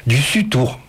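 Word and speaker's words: Dussutour: On va parler de, et on va l'entendre parler, d'Audrey Dussautour. Du Sutour, j'ai mal prononcé,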